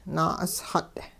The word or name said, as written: naa'usxut'